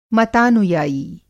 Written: मतानुयायी

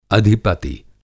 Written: अधिपति

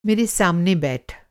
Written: सामना

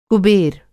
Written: कुबेर

